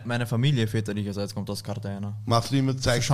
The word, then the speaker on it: Cartagena: Cartagena. – Cartagena.